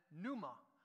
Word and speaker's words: pneuma: The Greek word for breathe or breath is pneuma. We would spell it in English, P-N-E-U-M-A, pneuma.